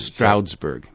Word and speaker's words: Stroudsburg: Stroudsburg. – How do you spell that? – It's S-T-R-O-U-D-S and then Berg, B-U-R-G. Stroudsburg.